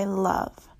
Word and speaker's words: Lauv: It's by Lauv.